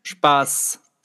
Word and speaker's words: Spaß: S-P-A-S, the weird end? – Spaß.